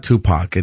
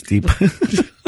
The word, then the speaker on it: Deepak: Gosh, I don't know how to pronounce his name, but it's not Tupac. It's Deepak.